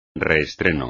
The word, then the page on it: reestreno: espagnol